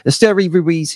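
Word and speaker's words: Esteury Ruiz: I've listened to a few videos and there's people pronounce it differently, but it's Esteri Ruiz, who's an outfielder.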